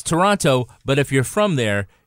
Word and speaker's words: Toronto: And speaking of which, I have been told by our listeners that if we don't pronounce it Toronto, they're going to give us a ton of shit. So I'm just going to go on a limb and just say it, guys. Say Toronto. Do not pronounce a second T.